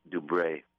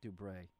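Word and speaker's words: Dubreuil: Help me out with Martin's last name. – Oh, it's difficult with these French names. It's Martine Dubray. – Dubray. Thank you. I would completely butcher that.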